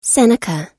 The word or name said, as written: seneca